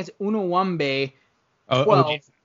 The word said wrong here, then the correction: Anunoby: OG Anunoby.